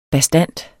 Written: bastant

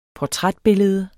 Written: portrætbillede

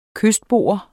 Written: kystbo

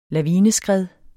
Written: LAVINESKRED

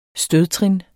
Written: stødtrin

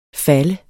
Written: falle

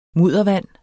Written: muddervand

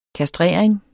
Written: kastrering

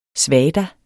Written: svadas

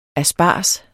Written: ASPARGESEN

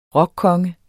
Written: rockkonge

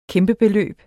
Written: kæmpebeløb

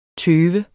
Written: tøve

